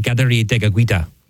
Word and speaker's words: Kateri Tekakwitha: But again, lots to talk about, and a great American saint today, Saint Kateri Tekakwitha, or as it would be pronounced by her and her people, it'd be Saint Kateri Tekakwitha.